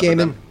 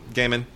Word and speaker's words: Gaiman: Gaiman. – Gaiman.